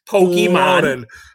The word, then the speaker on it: Pokemon: Pokemon. – And you actually pronounced it correctly.